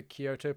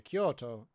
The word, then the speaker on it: Kyoto: Excuse me, I must correct your pronunciation. – Okay, Kyoto. – Very close, actually. Yeah, you're pretty much on Kyoto. You have to add that little. You have to add that little extension in there.